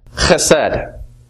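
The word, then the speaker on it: chesed (חסד): Hebrew word pronounced chesed.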